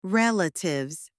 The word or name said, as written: relatives